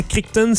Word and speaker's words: Crichton: On prononce souvent Crichton, C-R-I-C-H-T-O-N.